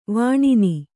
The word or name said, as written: ವಿನ್ನ